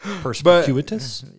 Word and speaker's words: perspicuous: Perspicuous? – Yes, it's perspicuous. Yeah. Yeah, that's right. I said the word and didn't butcher it.